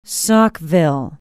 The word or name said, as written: Saukville